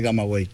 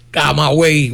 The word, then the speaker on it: Camagüey: De Camagüey. – Camagüey, coño, buena pronunciación, gracias.